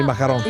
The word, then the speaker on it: macaron: E so, senti anche la pronuncia che è. – Macaron. – Macaron. Con la R deve finire.